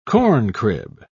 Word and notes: corncrib: British English

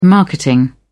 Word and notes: marketing: British English

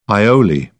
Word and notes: aioli: British English

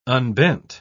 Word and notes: unbent: British English

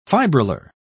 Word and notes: fibrillar: British English

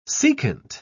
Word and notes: secant: British English

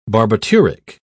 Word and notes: barbituric: British English

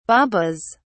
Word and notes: babas: British English

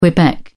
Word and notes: quebec: British English

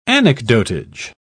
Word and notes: anecdotage: British English

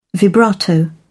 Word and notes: vibrato: British English

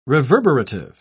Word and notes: reverberative: British English

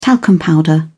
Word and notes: talcum powder: British English

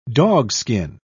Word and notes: dogskin: British English

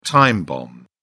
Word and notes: time bomb: British English

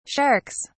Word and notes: sharks: British English

